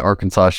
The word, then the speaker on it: Arkansas: states that Arkansas should be pronounced in three syllables with the final S silent, the A in each syllable with the Italian sound, and the accent on the first and last syllables.